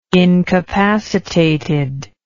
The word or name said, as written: incapacitated